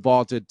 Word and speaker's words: Vlted: And by using the Vaulted Challenge feature, you can prove you're smarter than your friends. So go download the Vaulted app and give it a try for free. Vaulted is spelled V-L-T-E-D, and it's the app to challenge your friends, store your predictions, and join daily cash prize pools without any entry fee.